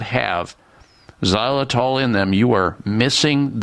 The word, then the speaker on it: xylitol: But if your current toothpaste, mouthwash, or mouth rinse or gum or mints, whatever you use, is not rich in xylitol, that's spelled with an X, X Y L I T O L.